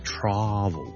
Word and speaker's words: travel: 譬如我讲 travel 呢个字 T-R-A-V-E-L travel，我哋读 travel 嘅，我哋就唔会读 travel 嘅。所以咧，即使系你系想讲英式英文咧，你都唔会读成 travel 嘅，but it's travel， travel， travel。